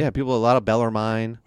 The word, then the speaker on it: Bellarmine: I just want to correct the entire lacrosse community on the pronunciation of Bellarmine. It is not Bellarmine, Bellarmine. It is Bellarmine. You don't pronounce the E at the end.